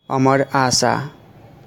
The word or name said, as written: Amar Asha